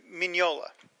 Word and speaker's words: Mignola: Is it Mignola? How do you say it? – I always thought it was Mignola. – Mignola. Okay. There's no N before the G, but it's Mignola is how it's spelled.